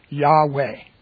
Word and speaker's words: Yahweh: I've been saying the name using this pronunciation, Yahweh. Yahweh, which is Y. Wish I had a whiteboard here to write this down, but it's Y-A-H-W-E-H. Yahweh.